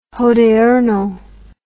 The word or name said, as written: hodiernal